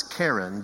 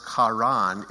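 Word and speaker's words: Karen (קרן): Now, horn is Karen, but when you pronounce it Karan, it's a verb, to horn, which is kind of weird.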